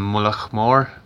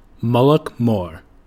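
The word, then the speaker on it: Mullaghmore: I'm not from Moloch Moore, but that's how it's said. – Where are you from in Ireland? – Wexford. – Okay, thank you. – Moloch Moore.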